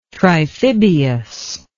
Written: triphibious

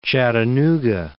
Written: Chattanooga

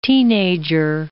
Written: teenager

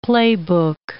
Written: playbook

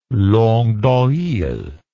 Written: langue doil

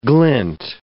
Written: glint

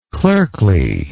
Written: clerkliness

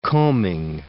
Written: combing

